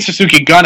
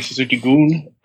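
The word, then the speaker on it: Suzuki-gun: And I don't mean Suzuki Gun. I mean Suzuki Goon as a run. But there's a lot of people mispronounce that.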